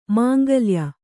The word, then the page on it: ಮಾಂಗಳ್ಯ: māngaḷya; kannada